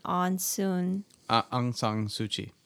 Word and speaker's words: Aung San Suu Kyi: Aung San Suu Kyi.